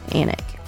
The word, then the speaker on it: Alnwick: Y'all Google Annick Castle, and I'm going to spell Annick for you because it is not spelled the same way we pronounce it, or they pronounce it in England. It is spelled A-L-N-W-I-C-K, and it's pronounced Annick.